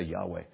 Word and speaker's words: Yahweh: We believe that the pronunciation for the four consonants is Yahweh.